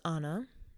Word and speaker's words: Anna: And that's spelled A-N-N-A, two N's. And it confuses everybody, and I get called Anna all the time. If your name is Anna, that's beautiful. It's lovely. It's just not my name.